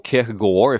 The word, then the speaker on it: Kierkegaard: It is an anagram of Kierkegaard, or Kierkegaard, if you want to try and say it like the Danes do.